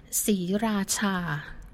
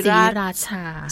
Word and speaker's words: Sriracha: Maybe we should start by how do you pronounce it? – Okay, Sriracha. The name is Sriracha.